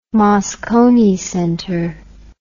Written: Moscone Center

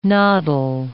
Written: noddle

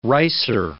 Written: ricer

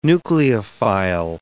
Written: nucleophile

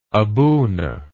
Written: abuna

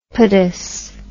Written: podous

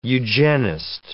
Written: eugenist

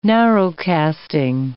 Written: narrowcasting

